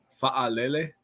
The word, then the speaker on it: Fa'alele: Number four, I have Daniel Fa'alele.